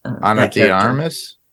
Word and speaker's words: Ana de Armas: Ana de Armas? – Yes.